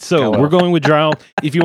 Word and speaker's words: drow: I say drow as well because that's how I've pronounced it since I started reading some certain series of books dozens of years ago. – It rhymes with cow.